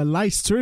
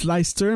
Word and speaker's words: Leicester: est né le 11 janvier 1945 à Leicester, ou à Leicester, je devrais plutôt dire. Ça se prononce Leicester, même si ça, en fait, ne vous fiez pas à la façon dont ça s'écrit.